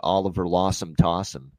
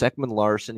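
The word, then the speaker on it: Oliver Ekman-Larsson: Oliver Lawson Tossum, as he would call it. That's the all-time Don Cherry mispronunciation right there. – Yeah, it doesn't get any fucking funnier than that. Because you know what he's thinking? He's here in it's Ekman Larson.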